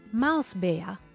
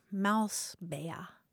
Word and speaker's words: Mausbär: Here's an adorable term of endearment from German, Mausbär. – So mouse, how do you spell that last part? – B-A with an umlaut, R, Mausbär.